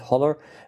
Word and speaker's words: HOLLER: So the best paper, this is a paper called, depending on how you want to pronounce it, Holler. Tim Dwyer presented that, and he called it Holler.